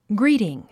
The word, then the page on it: greeting: gríːtiŋ; グリィティン